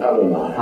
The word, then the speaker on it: Adonai: Yes, well, give us the right pronunciation. Adonai, Adonai? – Yes, it is Adonai.